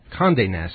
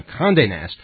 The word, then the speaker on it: Condé Nast: This is something that I thought was just simply brilliant, involving Condé Nast. Am I saying that right? – Condé Nast. – That's how you're supposed to say it? Okay. – Oui. – All right, fine. Condé Nast.